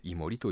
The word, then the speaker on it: 宮森: 宮森と書いて井森と読むというのはそういうことなのかもしれないなと。